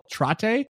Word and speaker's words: Trattay: It's called Trattay. Like a latte with a T.